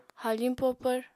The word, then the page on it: haafiñ popvr: Apatani